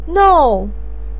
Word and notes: nou4: pinyin

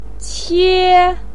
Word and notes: qie1: pinyin

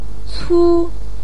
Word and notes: cu1: pinyin

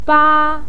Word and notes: ba: 1

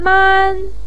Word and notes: man1: pinyin

